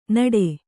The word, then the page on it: ನಡೆ: naḍe